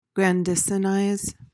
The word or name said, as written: grandisonize